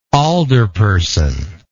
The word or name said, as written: alderperson